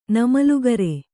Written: ನಮಲುಗರೆ

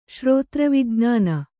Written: ಶ್ರೋತ್ರವಿಜ್ಞಾನ

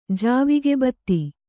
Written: ಜಾವಿಗೆಬತ್ತಿ

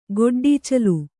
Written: ಗೊಡ್ಡೀಚಲು

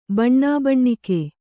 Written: ಬಣ್ಣಾಬಣ್ಣಿಕೆ

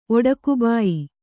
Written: ಒಡಕುಬಾಯಿ

